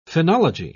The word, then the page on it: phenology: British English